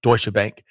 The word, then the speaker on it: Deutsche Bank: Deutsche Bank? Deutsche, is that how it's pronounced? – Deutsche Bank. – Thank you. – Deutsche Bank, yeah.